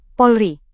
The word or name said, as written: Polri